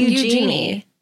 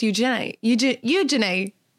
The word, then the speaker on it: Eugenie: Apparently we've been saying her name wrong the whole time. – Yeah. – It's not Eugenie. It's Eugenie. Yeah. – You got to get a little Cockney accent going there. – You have to say it like, use your knee.